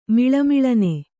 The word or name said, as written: ಮಿಳುಮಿಳನೆ